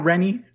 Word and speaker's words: Rennie: And did I say your last name right? – Yeah, Rennie. – Rennie. – Like a penny.